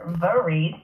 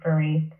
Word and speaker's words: Vareed: us so my name is Dr. Jennifer Vareed. it is a first last name. My parents are from Haiti. Um, so yeah, that E is silent, Dr. Vareed.